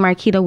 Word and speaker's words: Marquita: My name, Marquita Wilson. You spell that Marky with an A, Wilson.